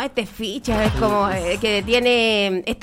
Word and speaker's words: Fixa: Ya, se llama Ficha, ¿Ficha? Pero se escribe F-I-X-A.